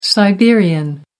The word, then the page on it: siberian: American English